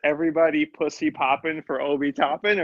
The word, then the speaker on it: Toppin: Toppin.